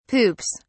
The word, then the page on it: poops: British English